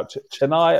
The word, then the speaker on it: Chennai: So yes, he's born in what's now Chennai.